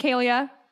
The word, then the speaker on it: Kahlea: So first name, Kalia, K-A-H-L-E-A. And then my middle name, Nicole, N-I-C-O-L-E with two E's at the end.